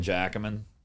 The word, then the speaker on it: Jacquemin: So basically, I always tell people, like, well, it only works in New Orleans if you can pronounce Plaquemine. – Plaquemine Jacquemin. There you go.